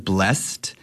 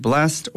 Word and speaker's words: blessed: If anyone is wondering, the word blessed can be pronounced blessed or blessed. – Blessed. – I think these days in modern English, we usually say blessed. But in old English, whenever I hear these songs, we've always sang it as blessed.